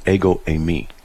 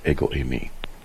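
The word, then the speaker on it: ego eimi (ἐγώ εἰμι): That would be I am is literally ego eimi. I am. That's how they say it. Ego eimi.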